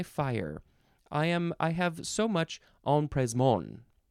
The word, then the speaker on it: empressement: Next is No. I added a syllable. Empressement. But it does have the M there in the second letter. It is spelled EMPRESSEMENT. Empressement. It's a French word, of course.